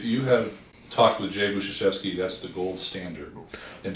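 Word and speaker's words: Budziszewski: And I went to a summer conference last summer and listened to a professor named Jay Budzewski. – Buzuszewski. – Thank you for correctly pronouncing his name.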